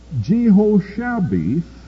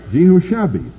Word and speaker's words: Jehoshabeth: "But Jehoshabeth," I wonder if that's the way to pronounce that. My text has the accent on Shab. I wouldn't have pronounced it that way, but that's the way they say to pronounce it. I think they're wrong, but I'm probably wrong.